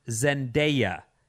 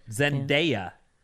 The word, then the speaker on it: Zendaya: This has to be the last one because we're out of time, but 510 says, her name is pronounced Zendaya, and she was also in The Greatest Showman. Yeah, I said that part, but Zendaya, everyone.